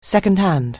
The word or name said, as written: second-hand